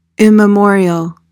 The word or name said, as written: immemorial